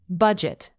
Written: budget

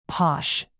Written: posh

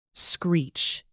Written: screech

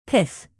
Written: pith

